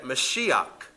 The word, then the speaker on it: Mashiach: Or better yet, Mashiach. Mashiach is how it's actually spelled or pronounced if you were reading it in Hebrew.